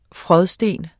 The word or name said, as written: frådsten